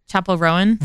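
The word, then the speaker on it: Chappell Roan: Chapel Rowan.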